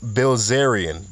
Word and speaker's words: Bilzerian: All right, Dan Blizzerman, or Dad Blizzalarian. How do you say the hell's name? Dan Bilzerian, the guy from Instagram that has all those fine women around him.